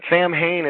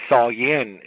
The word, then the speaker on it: Samhain: But Samhain is actually not the proper, you know, pronunciation of the word. It's actually Samhain is the way it's supposed to be said.